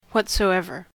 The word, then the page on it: whatsoever: American English